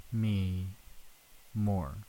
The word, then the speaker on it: Demi Moore: Demi Moore.